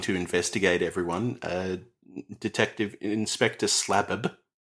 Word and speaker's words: Slabb: Because his name is spelt S-L-A-B-B. – Ah. – They pronounce the second B separately. – So ordinarily it would be Slab. – Yes. – But not in this case.